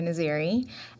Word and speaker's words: Naziri: Often. Naziri. Yeah. Naziri. I mean, you kind of like say it in many different ways, but it's okay. Just, you know, Jessica is fine too.